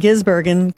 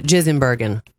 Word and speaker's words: Gisbergen: There are only three positions left to claim in the chase, with Bubba Wallace, Austin Cindric, and Shane Van Gisbergen currently occupying them. I'm Tammy Trujillo. – She said it wrong. Here on TOS, we pronounce it Gisbergen.